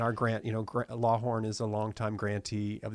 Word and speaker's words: Lawhon: Lawhorn, but it's spelled L A W H O N.